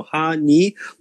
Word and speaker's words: 8ny: It is spelled 8NY honey. Ha is kind of a sound connecting sound to the word hachi, which means eight. So ha ni laundry. Okay, that's what it is.